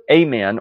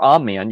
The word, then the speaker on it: amen: First of all, the word amen or amen, you'll hear it pronounced both ways in English. Neither is really technically correct, but we're not speaking Hebrew, we're not speaking Greek, we're English speakers. So either of these pronunciations is appropriate.